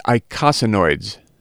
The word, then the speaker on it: eicosanoids: The pronunciation is eicosanoids, and I'll spell it for you.